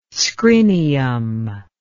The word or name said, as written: scrinium